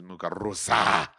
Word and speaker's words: Muguruza: Yeah, is that how you say her name? I do believe it is. Muguruza.